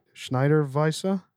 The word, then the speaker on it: Schneider Weisse: We're coming to the end here of the cigar, but first let's give this, let me pronounce it right. Schneider Weisse? Hey. – Yeah, it's good. – Am I doing all right? – Sure.